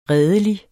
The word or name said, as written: redelig